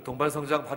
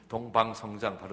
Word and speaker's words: 동반 성장: 여러분, 동반 성장 발음하기 굉장히 힘드시죠? 동반 성장하면 이응, 이은, 이응, 이응이라 이은이 이응화 돼갖고 동방 성장 발음들을 하게 되는데, 그냥 우리들한테 익숙한 동반 다음에 나오는 말은 어릴 때부터 있는 동반 자살 받기.